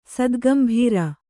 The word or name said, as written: ಸದ್ಗಂಭೀರ